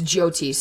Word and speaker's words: Jyoti: So Diana just brought it to my attention that I was saying it wrong. It's Jyoti, so I'm going to start saying it like that now.